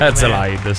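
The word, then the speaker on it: Herzeleid: Herzlide, dovrebbe essere questo. – Herzlide, sì. – Io ho detto bene. – Sì, sì, benissimo.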